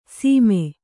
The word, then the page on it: ಸೀಮೆ: Kannada